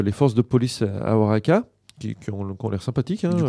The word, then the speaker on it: Oaxaca: Alors ça s'écrit Oaxaca, hein, mais on est très fort en mexicain, donc on prononce Oaxaca.